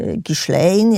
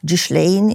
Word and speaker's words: Ghislaine: Kim jest Ghislaine, jak to jedni mówią, czy jak to prawidłowo powinno się wymawiać, albo Ghislaine, jak to ona sobie wybrała Maxwell.